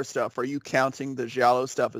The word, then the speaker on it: Giallo: And then he also dabbled in the, I can never pronounce this, the Giallo? – Giallo.